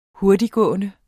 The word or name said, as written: hurtigtgående